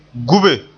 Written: gube

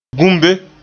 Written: gumbe

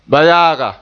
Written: bayaaga

